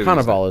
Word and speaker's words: Cannavale: That's a kind of how you say, is it Cannavale or Cannavale? – I mean, it depends on how pretentious you want to be. – Cannavale? I don't know, how do you say it? You can always say Cannavale is what I would do. The Americanized pronunciation.